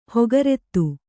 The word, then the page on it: ಹೊಗರೆತ್ತು: hogarettu